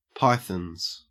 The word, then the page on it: pythons: Australian English